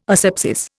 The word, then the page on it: asepsis: Indian